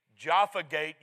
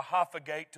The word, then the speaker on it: Jaffa: There's the Jaffa Gate, you would pronounce it. They call it the Hapha Gate to the west.